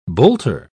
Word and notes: bolter: British English

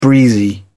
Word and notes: breezy: British English

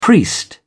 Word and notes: priest: British English

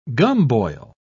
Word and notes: gumboil: British English

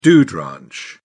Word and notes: dude ranch: British English

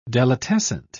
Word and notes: delitescent: British English

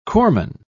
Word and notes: corpsman: British English

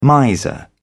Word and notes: miser: British English

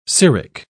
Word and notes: ceric: British English

